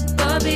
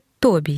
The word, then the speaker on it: тобі: мордою любов, коли тобі її даю гаряча кава і двоє під ковдрою Бери любов, коли тобі її даю. Однак правильно ж не тобі, а тобі.